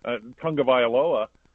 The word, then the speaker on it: Tagovailoa: You know, it makes, I don't know, the offensive line of Miami is not that good, but they got Tua Tagovailoa back there.